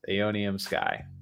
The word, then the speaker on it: Aeonium: Aeonium Sky is the correct pronunciation.